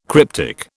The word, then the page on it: cryptic: British English